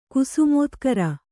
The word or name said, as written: ಕುಸುಮೋತ್ಕರ